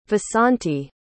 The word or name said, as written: vasanti